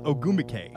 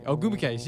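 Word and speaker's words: Ogwumike: Oh, how do you say that name? – It's Erica Ogumike. – Ogumike.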